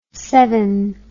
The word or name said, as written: sevin